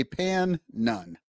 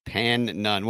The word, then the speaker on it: Pannone: I think it's spelled P-A-N-N-O-N-E. So I'm going to say Pan Nun. – Pan Nun.